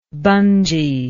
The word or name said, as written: bungee